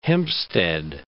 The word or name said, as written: Hempstead